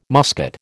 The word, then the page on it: muscat: American English